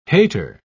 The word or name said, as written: hater